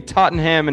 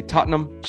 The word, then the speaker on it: Tottenham: Because in England, I learned you don't pronounce the N-ham. It should be Tottenham and Cheltenham. But yeah. Tottenham taught me that's just pronounced num. So you say Tottenham, Cheltenham.